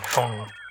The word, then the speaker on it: Phaune: I mean, phone radio. It's spelled P-H-A-U-N-E. But it's pronounced phone.